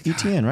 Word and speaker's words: Etienne: I mean, because the way Travis Etienne or whatever his name is, You said it right. Good job. – Is that right? – Yeah, Etienne, right? – It just doesn't look right.